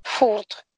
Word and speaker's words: Фурд: Фурд. – Угу. – Фурд. – Да, на конце D тоже немножко приглушенная.